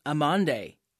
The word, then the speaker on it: amande: In French, it's pronounced amande. In Portuguese, amendoa.